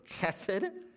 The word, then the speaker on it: chesed: It's a word that in the English is spelled H-E-S-E-D, but it's really a hard sound, if you can say it with one another without spitting on each other. It's the word chesed.